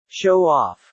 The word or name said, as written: show off